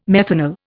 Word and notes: methanal: Indian

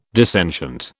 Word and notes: dissentient: British English

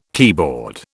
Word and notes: keyboard: British English

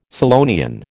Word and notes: Scillonian: American English

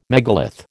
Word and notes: megalith: American English

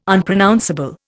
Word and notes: Unpronounceable: Indian

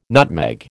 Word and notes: nutmeg: American English

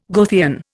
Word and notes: Goethean: Indian

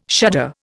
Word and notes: shudder: Indian

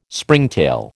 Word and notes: Springtail: American English

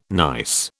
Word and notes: gneiss: British English

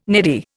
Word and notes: neddy: Indian

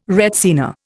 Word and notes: retsina: Indian

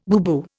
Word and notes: booboo: Indian

